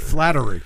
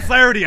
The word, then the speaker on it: Flaherty: He pronounced it wrong, though. He said Flattery. – Ryan Flaherty.